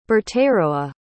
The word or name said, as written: Berteroa